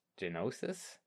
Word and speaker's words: Geonosis: G-E-O-N-O-S-I-S. Geonosis? Something like... – Geonosis. – Excellent, thank you.